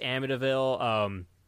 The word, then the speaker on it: Amityville: It's pretty much just Amityville, Amityville.